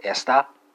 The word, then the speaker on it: esta: You have esta, so it's a emphasis on the E right there, and finally you have essa, right?